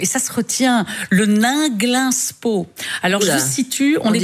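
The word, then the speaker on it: Ninglinspo: C'est écrit comme ça se prononce. N-I-N. – Absolument. Nainglinspo, avec O au bout.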